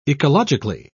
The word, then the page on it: ecologically: British English